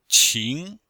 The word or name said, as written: qing2